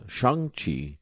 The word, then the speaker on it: Shang-Chi: shang-chi and the Legend of the Ten Rings. I'm going to say it like that because that's how they said it in the movie.